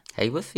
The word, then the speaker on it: Haworth: And that is, I don't know how you say it, Haworth? – Haworth, yeah.